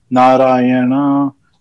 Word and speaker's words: Narayana: So it could be Narayana, like really Narayana. Short Narayana. Yeah, okay. – Narayan. So again, Narayana. – Yes, but go ahead. – But it is four syllables.